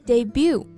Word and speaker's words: debut: 然后呢，第二个音节不要发成了 but，但是这个单词的发音啊，而是发 bu bu，词尾的字母 t 不发音，所以是 debut。